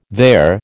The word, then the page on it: there: American English